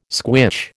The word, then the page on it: squitch: American English